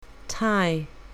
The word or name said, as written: tie